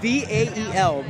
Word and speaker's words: Vael: I'm Vael. How do you spell that? V A E L, Vael.